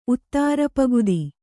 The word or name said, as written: ಉತ್ತಾರಪಗುದಿ